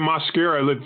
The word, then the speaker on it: Mascara: Yeah, you can just say it Mascara. Since it's a Spanish name, of course, your folks in Texas are very familiar with the Spanish language.